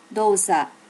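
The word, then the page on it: dosa: native Japanese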